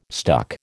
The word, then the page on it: stuck: American English